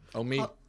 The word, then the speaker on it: Omeath: Omeath, County Louth.